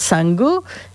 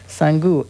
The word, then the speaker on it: Sangu: And it's called Sangu. And it's a little joke on him from his family. I'll let you look that up. Sangu, S-A-N-G-U. The accent on the U because of the way it sounds.